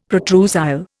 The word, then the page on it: protrusile: Indian